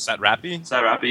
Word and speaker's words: Satrapi: Anyway, so this is from Margene Satrapi, I think is how you pronounce it. – Satrapi, yeah.